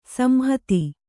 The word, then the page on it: ಸಂಹಿತ: samhita